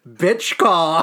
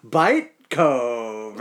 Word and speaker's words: Bychkov: I swear to God, his name, if you just pronounce it how it looks, is Roman Bitchkov. His name is Roman Bychkov. Bitchkov. Can't wait to hear Doug Armstrong. Darren Pang pronounced that as Bite Cove or whatever.